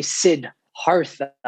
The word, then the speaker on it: Siddhartha: There's an H R T H A, right? Yeah. So they they they say Sid Hartha, as if it's two separate words. – Uh, that's the that's how they say it.